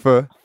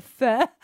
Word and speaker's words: Pho: It sounds like a noise you make at the doctor when he's checking out if you've got a throat problem. – Fur. Can I have a bowl of fur?